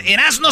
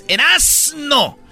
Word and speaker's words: Erazno: Erasno se escribe con Z, señores. Erasno. E-R-A-Z-N-O. Erasno.